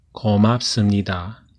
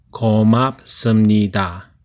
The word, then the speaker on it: 고맙습니다: Gomapsumnida. Thank you. Cuando habla con un superior o un maestro o la gente mayor, repita conmigo. Gomapsumnida. Despacio. Gomapsumnida. Gomapsumnida.